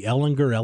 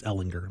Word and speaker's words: Ellinger: Sam was a sophomore or a junior, and I said, Sam, what's the correct pronunciation of your last name? He's like, people mispronounce it all the time. I'm like, well, it's your name. You should want the correct pronunciation. He's like, okay, it's Ellinger. I'm like, thank you.